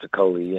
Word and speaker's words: Okolie: And, and that's and how do you say it? Lawrence Okolie, is it? Lawrence Okolie, yeah.